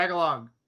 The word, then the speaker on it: Tagalog: But now I think about it, how did he even know Tagalog? Am I saying that right? Tagalog? – Yeah, Tagalog.